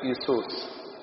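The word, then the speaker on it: إيسوس (Ἰησοῦς): وكلمة يسوع في القبطي وفي اليوناني تتنطق إيسوس، وأول حرف في كلمة إيسوس هو حرف اليوت.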